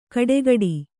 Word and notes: ಕಡೆಗಡಿ: kaḍegaḍi

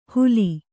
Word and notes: ಹೂಲಿ: kannada

